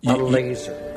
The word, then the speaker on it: Lazor: Here's how you pronounce it, Tommy. – Whatever it is. – Lazor.